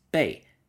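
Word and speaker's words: bei (貝): The ancient Chinese word for money, pronounced bei, is in fact a pictogram of a cowrie shell.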